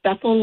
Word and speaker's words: Bethel: My email address is pbethel, and that's Bethel like the churches, at, I'm going to say it and then I'll spell it.